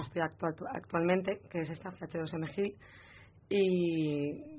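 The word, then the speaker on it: CH2M Hill: Y esta tarde también nos acompaña titulada, que actualmente está trabajando en la empresa CH2000 GIL, que es la ingeniería de ONO. No sé si lo he dicho bien. ¿Está bien dicho? – CH2M GIL. – M-GIL, bueno.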